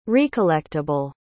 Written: recollectable

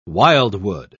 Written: wildwood

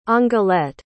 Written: angelette

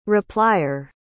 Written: replyer

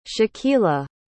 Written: shakila

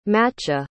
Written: macha

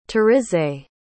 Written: tarisai

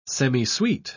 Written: semisweet

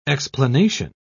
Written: explanation

